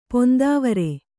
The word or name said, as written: ಪೊಂದಾವರೆ